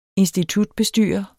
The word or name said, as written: institutbestyrer